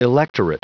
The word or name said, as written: electorate